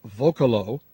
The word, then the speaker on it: Vocalo: The name, Vocalo, which is V-O-C-A-L-O, is an invention essentially vocal with an O at the end. It rhymes with Zocalo, a Spanish word that in Mexico refers to a town plaza, and in Colombia refers to the infrastructure that stabilizes a large building.